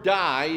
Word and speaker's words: dei: Daryl Bach notes that Luke uses the Greek word die, even though it's spelled D-E-I in the Greek, or delta epsilon iota, the word for must in our English translation.